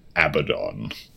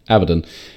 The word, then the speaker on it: Abaddon: Okay, that's our lowdown on how to pronounce Abaddon. – Yes, Abaddon.